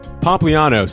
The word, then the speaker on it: Pompliano: Anthony Pompliano, say your last name.